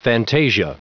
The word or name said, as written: fantasia